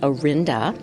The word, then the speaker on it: Orenda: But another idea was, if you look it up on Google, for instance, it says that the name is pronounced Orenda, like we do, but they say it's of Latin origin and possibly related to the Spanish word oro, meaning gold.